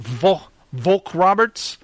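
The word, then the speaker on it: Vogt-Roberts: Unfortunately, director Jordan Volk Roberts... How do you say that? Volk Roberts? – Yeah.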